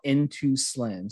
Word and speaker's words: N2 Slin: So one we get a lot of, and this was a question sent in about N2 Slin. So the way it's spelled is N, the number 2, S-L-I-N. And a lot of people get this confused because they think it's slim, S-L-I-M. It's S-L-I-N.